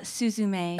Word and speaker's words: Suzume: It's Suzume.